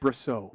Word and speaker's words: Brosseau: And the dude who broke the no-hitter up last night, I might be saying his name wrong, but Mike Brosseau, he's playing second base. – I think it's Mike Brosseau.